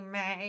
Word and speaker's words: me: It's gonna be Mei. – And it's spelled M-E, but it's pronounced like Justin Timberlake's Mei.